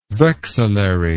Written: vexillary

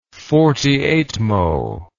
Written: forty-eightmo

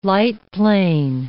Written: lightplane

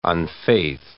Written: unfaith

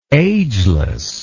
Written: agelessness

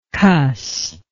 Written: cusser

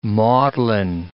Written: maudlin

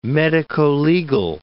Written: medicolegal

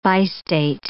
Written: bistate

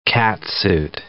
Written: catsuit

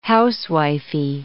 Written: housewifey

